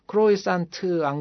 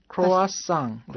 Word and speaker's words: croissant: 크루아상 발음하는 거 보니까 C-R-O-I-S-S-A-N-T 이러는데 발음은 크로이산트 안 그러고 크로아상. – 크로아상. – 네, 크로아상 이렇게 발음합니다.